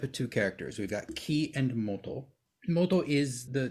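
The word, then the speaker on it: ki (生): Here it's pronounced ki, which has a nuance of life.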